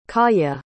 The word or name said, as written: kaya